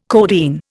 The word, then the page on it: codeine: Indian